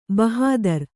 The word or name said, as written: ಭಾಹದರು